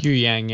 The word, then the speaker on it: Huyang: Huyang? Is that how you say it? – Huyang, yeah.